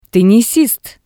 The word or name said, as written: tennisist